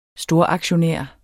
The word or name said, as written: storaktionær